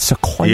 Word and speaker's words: Sequoia: So, I was surprised by the room, especially the headroom in the Sequoia, John. The Toyota. Is that how you say it? Sequoia? – Yeah.